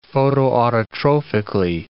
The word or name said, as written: photoautotrophically